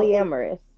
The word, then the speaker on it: polyamorous: Yeah, polymorous. Yeah. – So polyamorous.